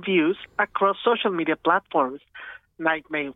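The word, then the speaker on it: NGHTMR: You also heard No Air, the remix by Nightmare, all caps, no I. We think it's pronounced Nightmare, but, I mean, we can't be sure. Mm-mm. Nightmare, no I and no A, according to the script,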